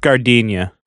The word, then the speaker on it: giardiniera: It's pronounced Gardinia.